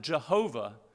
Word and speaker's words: Yahweh: So those four letters, YHWH, probably pronounced Yahweh. Interesting story, some people pronounce it Jehovah, because in another attempt not to take God's name in vain, they take the consonants from YHWH and insert the vowels from the Hebrew word Adonai, which means Lord, and they end up saying Jehovah.